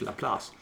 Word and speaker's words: Laplace: And the article references a guy named, and I want to pronounce it, I think the way it'd be pronounced in French, Laplace, L-A, capital P-L-A-C-E.